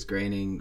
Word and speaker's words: Groening: I always thought it was Groening too, but apparently it's Groening.